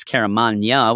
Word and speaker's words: Caramagna: I end up knowing now it's Caramagna, which I should have before because it rhymes with lasagna,